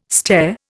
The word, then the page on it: stair: Indian